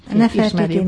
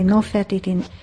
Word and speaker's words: Nefertiti: És Nefertitinek Nefertiti, Nofertiti, Nofretete, többféleképpen ejtik a nevét, egyszerűen azért, mert két szóból lett összetéve. Az egyik szó a Nefer, vagy Nofer formájában élt tovább a kopt nyelvben.